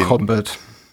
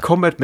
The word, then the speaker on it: Kombat: Mortal Kombat. – Ah. – Genau, schreibt man nämlich Kombat mit K, liest sich's lustig. – Okay. – Aber spricht sich nicht lustig.